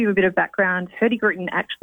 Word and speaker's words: Hurtigruten: Tell us a bit about who Hurtigruten are and where do you cruise? – Yeah, sure.